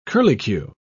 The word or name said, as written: curlicue